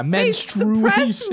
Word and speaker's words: menstruation: I just feel like that's lazy pronunciation. Menstruate and menstruation. There's a U, right? Am I spelling it wrong? – No. – You're spelling it right, but okay. We're pronouncing, like, people are pronouncing it wrong.